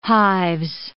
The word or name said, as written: hives